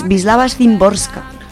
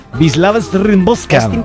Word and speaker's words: Wisława Szymborska: Al turrón, hoy la poetisa y ensayista polaca Wisława Wisława, bueno, se escribe Wisława con dos V's lobres, pero yo me he metido a ver cómo se pronunciaba, que lo digo fatal todavía porque he estado ensayando, os lo juro, pero no lo consigo. Es Wisława Szymborska.